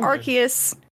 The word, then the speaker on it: Arceus: Yes, I have changed the pronunciation to be the standardized official pronunciation, because Arceus apparently has a bad word in it for our British counterparts. So I guess I'll be saying Arceus from now on.